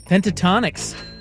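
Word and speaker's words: Pentatonix: Pentatonics. Is that how you pronounce that? – Oh, yeah.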